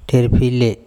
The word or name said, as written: TherpHile